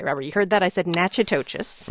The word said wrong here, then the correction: Natchitoches: The name of our city is pronounced Natchitoches. – Natchitoches. – Yes. So many people get it a little bit off. When you look at it, it looks like Natchitoches.